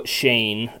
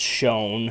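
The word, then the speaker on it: Schoen: His name's Joe Shane, even though it's pronounced Shone, and I'm going to have a tough time differentiating that.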